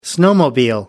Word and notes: snowmobile: American English

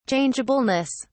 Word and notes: changeableness: British English

